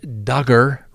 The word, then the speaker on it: Duggar: Next on the docket, Josh Duggar rhymes with hugger.